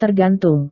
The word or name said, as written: tergantung